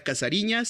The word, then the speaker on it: Cazarinha: Se llama La Casariña. Eh, entiéndase por pronunciación este, brasileña. Entonces es la casariña, la. Eh, déjenme los deletreo. – Es la. bueno, la C-A-Z-A-R-I-N-H-A este, CUU, pues de Chihuahua.